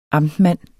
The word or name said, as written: amtmand